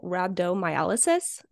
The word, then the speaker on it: rhabdomyolysis: um, so my article is called The Risk of Exertional Rhabdomyolysis. Did I say that right, Wes? – Yeah.